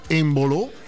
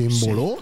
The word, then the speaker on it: Embolo: Giocatore Embolo. Penso che si pronuncia in bolo, sì.